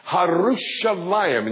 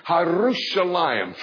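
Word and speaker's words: Harushalayim (ירושלים): Let me tell you how you say it in Hebrew. Harushalayim. And you have to twirl your tongue. Harushalayim. Try that. Harushalayim.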